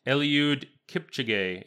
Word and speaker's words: Eliud Kipchoge: I'm going to guess that it's Eliud Kipchoge, if I know how a lot of Kenyans are pronounced. – I think that's beautifully. Thank you. I think that was beautifully done.